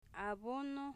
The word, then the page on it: abono: a'bono